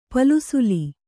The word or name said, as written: ಪಲ್ಸುಲಿ